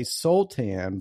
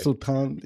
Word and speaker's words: Sultan: So I would say Soltan, but how would you say it? – I mean, that is a totally acceptable answer. but like if my Latin grandma was here, it would be Ryan Sultan. That's what she would say. – Sultan. Yes, Sultan. I like it. It's very good. – Accent on the A.